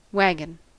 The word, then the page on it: waggon: American English